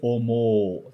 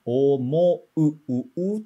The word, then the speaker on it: 思う: Agora tem o, mas a gente não estica o, ok? Não vai ser omo, né? É omo, u, tem que ser pronunciado direitinho.